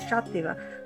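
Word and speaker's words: Xàtiva: Por ejemplo, una de las ciudades más bonitas y con mucho valor histórico es Shativa.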